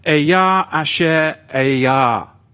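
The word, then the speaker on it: Ehyeh Asher Ehyeh (אֶהְיֶה אֲשֶׁר אֶהְיֶה): The signature is the Chaya Asher Chaya, pronounced Ehyeh Asher Ehyeh.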